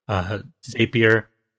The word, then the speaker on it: Zapier: You know, whether it's using workflow or if this, then that, or Zapier, found out that's how it's pronounced recently.